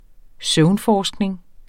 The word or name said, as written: søvnforskning